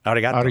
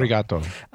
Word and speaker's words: arigato: Arigato for that very much. Did I say that correctly? I know you can say it. – Yeah. – Arigato. – Arigato.